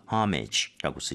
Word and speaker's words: homage: 오마주는 이제 발음을 할때 앞쪽에 강세를 주면서 homage라고 쓰시면 되겠습니다.